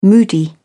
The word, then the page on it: moody: British English